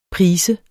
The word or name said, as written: prise